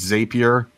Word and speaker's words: Zapier: And if you have heard of Zapier and you think, oh, it's Zapier, wrong. It's pronounced Zapier because the owner, Wade himself, told me, Zapier makes you happier.